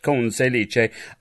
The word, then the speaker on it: Conselice: That's how his name has to be pronounced. I just love it. Conselice.